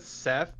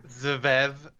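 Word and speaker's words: Szeth: I pronounce it Seth, but it's spelled S-V-E-T-H. – Yes. – So it's like Zvev, Seth. The audiobook I listened to just called him Seth. So we'll just call him Seth.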